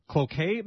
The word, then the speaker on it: Cloquet: Jay is with us in Cloquet, Minnesota.